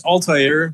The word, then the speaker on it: Altair: Altair? Is that how you say it? Or is it Altair? – So, it's Altair, basically from that video game Assassin's Creed 1.